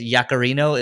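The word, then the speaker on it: Yaccarino: So Yak is, I guess, Yakarino is, that's how her last name is pronounced.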